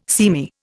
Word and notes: seamy: Indian

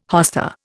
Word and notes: hosta: Indian

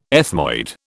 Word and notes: ethmoid: British English

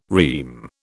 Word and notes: REME: British English

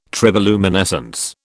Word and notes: triboluminescence: British English

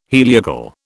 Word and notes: heliacal: British English

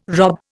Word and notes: rob: Indian

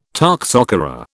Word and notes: toxocara: British English

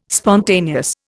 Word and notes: spontaneous: Indian

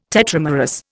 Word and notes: tetramerous: Indian

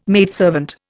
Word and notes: maidservant: Indian

